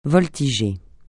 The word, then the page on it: voltiger: francais